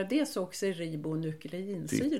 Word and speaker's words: desoxyribonukleinsyra: Och jag tror det står för Deoxyribonukleinsyra. – Ja, vi Visste du kemister, det? Ja, oh ja. Det är mitt bord. Vi kemister brukar uttala desoxyribonukleinsyra. – Titta, det, så låter det.